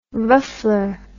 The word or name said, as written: ruffler